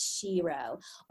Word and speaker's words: Sherot: So it's spelled Sherot, just like tarot, it's Shiro.